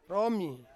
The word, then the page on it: romi: Ansus